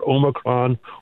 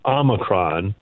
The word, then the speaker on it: Omicron: Well, it turns out that both are acceptable pronunciations. So either Omicron or Omicron are both acceptable pronunciations.